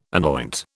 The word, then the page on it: anoint: British English